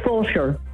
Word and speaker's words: Fulshear: It's Fulshear.